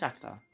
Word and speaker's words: Shakhtar: It's pronounced Shakhtar.